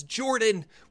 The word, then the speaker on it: Jorphdan: My name is Jordan, with a silent PH in the middle, and I am joined always by my wonderful co-host, Sir Lucian, over there at Sir Lucian Gaming.